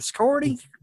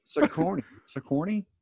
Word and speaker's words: Sigourney: You know, Weaver lady, the Scorny, you know, Scorny Weaver. – Weaver lady. – Anyways, Sigourney Weaver. And I know I'm going to get some feedback on this. This is how you pronounce it.